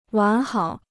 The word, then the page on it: 完好: wán hǎo